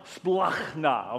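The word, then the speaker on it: splachna (σπλάγχνα): The second word is pronounced, you can't spell it in English, okay? And if I put the Greek spelling up there, you wouldn't know what to do with it anyway. So it's pronounced splachna, okay?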